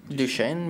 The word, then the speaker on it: Duchenne: Duchenne muscular dystrophy, I hope I'm saying that right. Duchenne? D-U-C-H-E-N-N-E. – Sounds... – Duchenne, maybe? – Duchenne. – I mean, it's you're not French. I don't expect you.